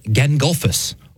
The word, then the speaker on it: Gangolf: And it, yes, it's spelled very much like the wizard from the Lord of the Rings, but this is G-A-N-G-O-L-F. So G-A-N and then golf, Gandalf.